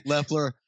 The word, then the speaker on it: Loeffler: Loeffler.